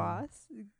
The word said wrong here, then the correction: Bosh: Chris Bosh.